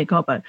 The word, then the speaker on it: Gobbo: Is that how I pronounce her name? – That's right, yeah. – Yeah, Gobbo. Published earlier this month, the book is Lawyer X.